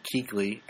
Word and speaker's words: Kuechly: So, you guys have the scariest thing possible, which is Luke Kuechly. And we don't know if we're pronouncing his name right. – You are. That's right.